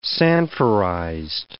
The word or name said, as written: Sanforized